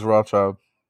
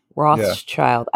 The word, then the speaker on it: Rothschild: I think it's ROTH, Rothschild. Yeah. I think they pronounce it like Rothschild or something like that. Basically this dad, right? He had four sons, three sons. He got a couple sons. And he like sent them to different parts of whatever country they're from. Something where you pronounce child like shield, basically.